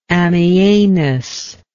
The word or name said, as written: ammianus